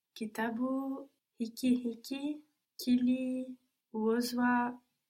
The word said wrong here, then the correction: kiliuzwa: juzi. – Kitabu hiki kiliuzwa juzi.